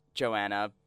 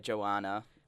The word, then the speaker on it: Joana: It's Late Night Party Boyz, and we're here with who I know as Joanna, but goes by Joana. – Well, that's my actual name. That's how you pronounce it. – You've just been insulting her the whole time. – Really? – By mispronouncing her name. – Well, the thing is like, so my name is Joana. – Is it though? – Like Moana. Okay.